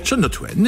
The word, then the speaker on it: Shania Twain: Shania Twain. – Shania Twain, exactement.